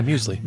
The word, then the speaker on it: Muesli: But the song that I'm going to use for the mixtape is a song called, I'm not sure if I'm even saying this right, it's M-E-U-S-L-I, Muesli Dreams. – Yeah, Muesli.